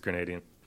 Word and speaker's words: Grenadian: Grenadian. Or Grenadian. – I think Grenadian. – I think it's Grenadian. – Rhymes with Canadian.